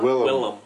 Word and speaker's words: Willem: Well, it came up, could have given William an act. I never know how to say his name. Mr. Defoe. – Willem. – I don't like saying Willem.